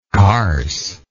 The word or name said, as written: carse